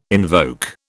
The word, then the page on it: invoke: British English